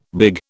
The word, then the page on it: big: British English